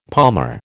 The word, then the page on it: palmer: American English